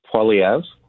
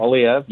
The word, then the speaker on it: Poilievre: And if Pierre's going to win, you've got to learn to say his name. That's Poilievre. – I know, I say it wrong all the time. – Poilievre, yeah.